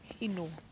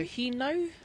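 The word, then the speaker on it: hinau: Heno. How do you spell that, Jenny? – H-I-N. – Yeah, so heno.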